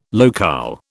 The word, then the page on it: locale: British English